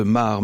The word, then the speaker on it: Maere: Je pense à vous, le chanteur belge Pierre de Mar. Oui, on dit Pierre de Mar, me dit Isabelle Fiot. Ça s'écrit M-A-E-R-E. On a tendance à dire Pierre de Mer, mais c'est Pierre de Mar qui sera d'ailleurs en concert à l'Alhambra le 23 janvier 2027.